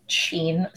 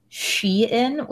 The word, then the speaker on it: Shein: People pronounce it Sheen sometimes. They don't know how to pronounce it. It is, in fact, Shein, which is short for She Inside, which is...